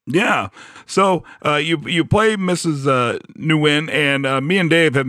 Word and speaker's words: Nguyen: Nguyen? – Nguyen. But in Vietnamese, it's like Nguyen. But I think in America, they pronounce it as like Nguyen.